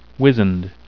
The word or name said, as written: wizened